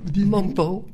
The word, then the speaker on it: Mompou: Mompou, j'ai du mal à prononcer. Dites. – Mompou. – Oui, voilà. Parce que c'est du catalan, c'est compliqué à dire, mais bon.